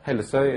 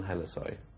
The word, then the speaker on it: Hellesøy: My last name is pronounced in Norwegian Hellesøy. Hellesøy, it's a small island west of Bergen, where my grandfather lived. So he actually lived on Hellesøy.